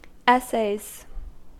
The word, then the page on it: essays: American English